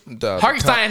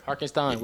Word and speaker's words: Hartenstein: Say his name right. – Hartenstein. Whatever you want. Hartenstein. Not Hartenstein.